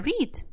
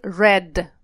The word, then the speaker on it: read: Он не меняет свое написание в прошедшем времени, но меняет произношение. Я читаю будет I read books, а я прочитала I read books.